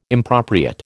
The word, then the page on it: impropriate: American English